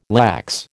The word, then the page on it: lax: American English